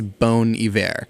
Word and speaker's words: Bon Iver: And yes, yes, I will be a douchebag and pronounce it correctly. It is Bon Iver. I called it Bon Iver, and I got stabbed in like point blank. Like, I pronounced it wrong, and some dude with a ponytail and a white tank top was like, "It's not pronounced like that," and he stabbed me, all for pronouncing Bon Iver wrong.